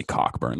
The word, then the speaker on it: Cockburn: Hard K too. It's like Cockburn. It's definitely Cockburn. Like also in the NFL, there's this guy, his last name is spelled K-O-C-H, and that's come from a German background.